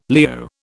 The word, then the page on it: Leo: British English